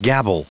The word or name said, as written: gabble